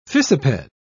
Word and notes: fissiped: British English

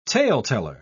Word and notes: taleteller: British English

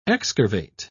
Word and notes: excurvate: British English

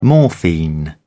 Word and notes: morphine: British English

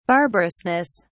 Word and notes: barbarousness: British English

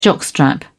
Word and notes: jockstrap: British English

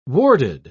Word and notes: warded: British English

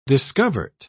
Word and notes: discovert: British English

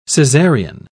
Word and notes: cesarean: British English

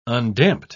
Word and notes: undamped: British English

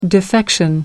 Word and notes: defection: American English

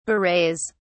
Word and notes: berets: British English

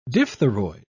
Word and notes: diphtheroid: British English

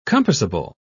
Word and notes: compassable: British English

